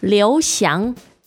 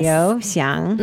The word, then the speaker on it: Liu Xiang: Can you tell us the proper pronunciation of his name? – Liu Xiang. Liu Xiang. – So the both second tone, right? – Yes. – Liu Xiang.